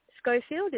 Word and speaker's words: Scholfield: Schofield. It's spelled S-C-H-O-L-F-I-E-L-D.